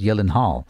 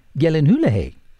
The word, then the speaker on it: Gyllenhaal: I know in Hollywood people mispronounce his name as Gyllenhaal, but in actual fact, there's a video on YouTube by Jake saying that the actual pronunciation of his name is Gyllenhaal.